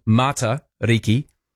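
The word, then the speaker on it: Matariki: Hi, Roman, can you please stop saying Mā Tariki? It's a short sound, Mā Tariki. Don't elongate the sound.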